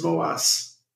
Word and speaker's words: Moas: You do know how to pronounce my name, though, right? – It's Moas. – No, it's Moas. – Moas. – Moas. – More ass? I thought it was more ass. – There's an R in there.